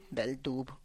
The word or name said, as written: beldub